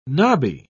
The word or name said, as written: nobby